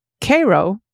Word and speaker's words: Cairo: It's Cairo, like the letter K.